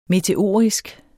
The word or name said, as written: meteorisk